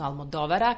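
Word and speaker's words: Almodóvara: Almodovara, odnosno Almodovara, kako se pravilno akcentuje, a to nije slučajno.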